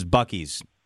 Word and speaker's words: Buc-ee's: They are a big company with a lot of money, and they market themselves as Buc-ee's.